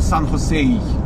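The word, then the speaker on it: San Jose: Его по-разному произносят. Это тихий ужас. Там одни говорят Сан-Хосе, другие говорят Сан-Хосе, третий говорит Сан-Хосей. Вот. Правильно, если вы будете смотреть там, чего пишут люди, то там типа Сан-Хосей.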